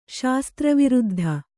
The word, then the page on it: ಶಾಸ್ತ್ರವಿರುದ್ಧ: śastra viruddha